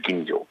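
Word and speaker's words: Ginjo (吟醸): Ginjo is G-I-N-J-O. – Ginjo. Ginjo. – We're really tempted to pronounce that Ginjo. – Yes. – But it's Ginjo.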